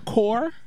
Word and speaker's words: corps: Core kills me. C-O-R-P-S. – Yeah. – Kills me. Just C-O-R.